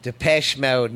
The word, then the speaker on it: Depeche Mode: Depeche Mode, not Depeche.